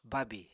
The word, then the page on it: bábì: apatani